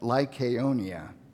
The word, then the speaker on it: Lycaonia: cities of I have been saying this wrong all week. Let me see. They learned of it. They fled to Lystra and Derbe, cities of Lycaonia. That's how you say that. Lycaonia.